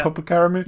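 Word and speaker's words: Popokarimu: However, some of the most interesting fights in this game, like, I'm going to butcher this name probably, but the Popo Caramu. Is it the Popo Caramu? – Yes.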